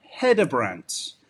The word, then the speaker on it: Hedebrandt: No, it's more like you don't push hard on the D, so in Danish it would be Hedebrandt.